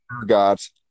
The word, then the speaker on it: ergot: And one of the popular explanations is something called is it ergot? I want to call it ergo, but that's not the pronunciation. It's ergot.